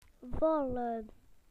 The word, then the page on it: to fall: British English